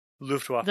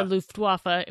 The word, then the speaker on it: Luftwaffe: The second one, I believe, was with a Luftwaffe. Is that how you say it? – Luftwaffe. – The Luftwaffe.